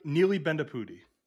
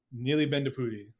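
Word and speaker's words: Neeli Bendapudi: Neely Bendapudi. – Neely Bendapudi.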